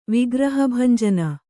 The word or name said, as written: ವಿಗ್ರಹಭಂಜನ